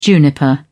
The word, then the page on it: juniper: British English